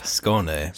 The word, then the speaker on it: Skåne: S-K-A with an O thing above it, N-E. – Sounds like Scone Town, like they got a lot of scones there. – No, but you know what's cool is Skåne has a coat of arms,